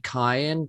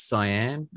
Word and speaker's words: cyan: For years I said cayenne, but apparently it's cyan.